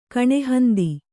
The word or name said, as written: ಕಣೆಹಂದಿ